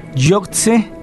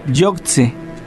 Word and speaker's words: Yogtze: Yoktse se escribe con Y-O-G-T-Z-E, Yoktse.